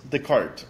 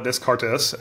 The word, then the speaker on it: Descartes: What is Descartes Underwriting? – Yeah, so first off, thanks for saying it correctly. Most people call it Descartes.